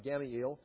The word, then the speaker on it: Gamaliel: And Gamaliel, that's how you say his name, Gamaliel, he says, let's just wait and see what happens to these people.